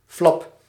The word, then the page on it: billet: France; IPA: [bi.jɛ]